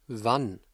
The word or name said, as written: wann